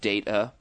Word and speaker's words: Data: Last one from Chris in Arlington, Virginia, tells us that Data in the movie Goonies is actually pronounced Data, not Data.